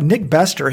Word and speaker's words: Nick Bester: And then there was another article about Nick Bester. He won the Comrades Marathon in South Africa like 20 or 30 years ago. – Glad to see you pronouncing it correctly. Good job.